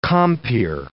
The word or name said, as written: compeer